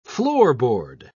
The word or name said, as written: floorboard